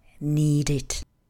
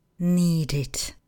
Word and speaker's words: needed: Minulý tvar zní. Needed. Needed. Vyzkoušejte si to ve větách.